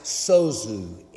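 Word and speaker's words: sozu (σῴζω): That word that's been interpreted in many of our translations of scripture in modern day, save, that word is pronounced sozu in Greek.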